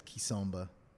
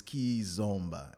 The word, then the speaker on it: kizomba: And uh, hell, even I mispronounced it for a long time. I call, I said kisomba because like you know, it's kizomba, and you know, um, it means parties from Angola.